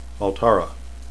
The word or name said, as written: altara